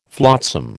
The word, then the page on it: flotsam: American English